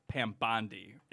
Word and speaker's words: Bondi: And finally, after like saying Bondi for like the 10th time, one of our employees said, I think it's pronounced Pam Bondi, isn't it?